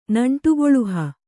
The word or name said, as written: ನಂಟುಗೊಳುಹ